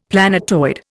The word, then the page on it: planetoid: Indian